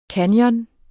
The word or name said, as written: canyon